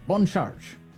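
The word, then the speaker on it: Bon Charge: Boncharge. That's the way you say it. A little French pronunciation.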